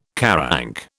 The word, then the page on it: QARANC: British English